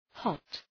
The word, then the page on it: hot: hɒt